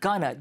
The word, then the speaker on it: gonna: Going to를 빠르게 발음하면 gonna, G-O-N-N-A처럼 발음이 되긴 합니다.